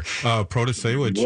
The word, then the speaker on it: Protasiewicz: Well, Protasiewicz, I got to say it right there. Protasiewicz. – Well done.